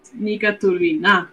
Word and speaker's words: Turbina: como una va en realidad se, pronuncia distinto en, ruso. Es Nika Turbina. Ah. – Y nosotros le cambiamos el acento para que suene turbina.